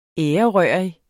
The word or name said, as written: ærerørig